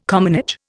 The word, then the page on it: commonage: Indian